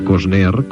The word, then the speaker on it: Costner: Después del Guardiola, felicitamos a Kevin Costner, que hoy cumple 45 años. ¿Lo digo bien? – Fantástico, Costner, como tiene que ser.